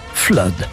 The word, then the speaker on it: flood: Bref, ces 4 exceptions se prononcent soit comme cut ou mud, ce sont le sang, blood, et l'inondation, flood.